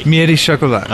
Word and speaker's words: Miette Chocolat: Miette Chocolat.